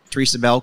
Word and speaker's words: Therasa Bell: Therasa Bell, co founder, president, and CTO of Kno2.